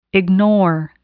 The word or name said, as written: ignore